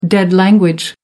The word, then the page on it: dead language: American English